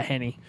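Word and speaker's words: Henne: Is that how you say his name? Henny? However you say his name. – Yeah, Henny.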